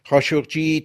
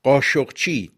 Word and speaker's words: خاشقجی: خاشقجی تلفظ عربی کاشقچی ترکی است که در فارسی قاشقچی تلفظ می شود.